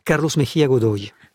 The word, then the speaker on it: Carlos Mejía Godoy: Carlos Mejía Godoy.